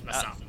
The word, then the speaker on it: Mossop: M-O-S-S-O-P. I probably said that wrong. – Jeff Mossop.